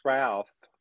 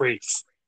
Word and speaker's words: Ralph: It says Ralph. – It's pronounced Rafe. – Oh, whatever. Ralph Fiennes is in it. – Rafe. Get it right, Pally.